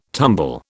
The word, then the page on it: tumble: British English